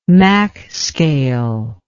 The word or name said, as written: mach scale